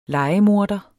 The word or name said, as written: lejemorder